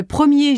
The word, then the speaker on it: premier: The ER in premier and dernier has two different pronunciations. E, as in E, when it ends a sentence or is followed by a word starting with a consonant, and R, as in mer, when it precedes a word beginning with a vowel sound.